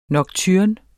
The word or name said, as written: nocturne